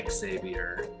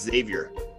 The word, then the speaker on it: Xavier: When you say Xavier. – No, it's Xavier. – Xavier. – Yeah, you don't say the X.